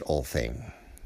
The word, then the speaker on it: Alting: And the Alting is, it can be pronounced in English, all thing.